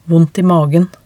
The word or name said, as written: vondt i magen